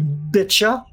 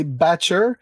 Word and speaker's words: Batcher: Je pense qu'elle l'a appelé Betcha. – C'est Batcher, mais avec son accent anglais très prononcé, ça sonne comme Betcha.